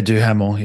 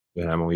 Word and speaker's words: Duhamel: Josh Duhamel. How do you say his last name? – Yeah, Duhamel. Yeah. – Duhamel.